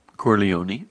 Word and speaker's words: Corleone: I don't even know how to say this name. Corleone? What is that? How do you say that, crime family? – Corleone. – Corleone. All right. It's Corleone family.